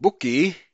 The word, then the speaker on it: Bukki: Phinehas begat Abishua, and Abishua begat Bukki, or Bukki, B-U-K-K-I. Let me see what it says here, if that has a meaning. Meaning wasteful! Bukki. No, they pronounce it Bukki.